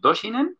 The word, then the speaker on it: Dohsinen: Que bueno, ¿qué es el método Dohsinen? ¿Lo digo bien, Andrés? – Sí, muy bien.